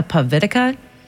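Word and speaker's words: povitica: and I did what's called, I think it's pronounced a pavitica, which is like a traditional Croatian nut roll.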